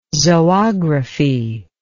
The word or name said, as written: zoography